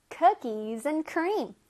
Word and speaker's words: cookies and cream: Cookies and cream. 쿠키 앤 크림이 아니라 cookies and cream.